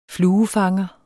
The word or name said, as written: fluefanger